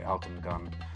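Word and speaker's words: Altın Gün: They are an Amsterdam-based Turkish band, and I looked up the pronunciation because I thought it can't be as simple as Alton Gun, but it is actually Alton Gun.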